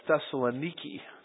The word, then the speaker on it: Thessalonica: Actually, when I was in Thessalonica, they all talked about it as Thessaloniki. So I can't even get the pronunciation right on this, but it looks to me like Thessalonica, where there was a synagogue of the Jews.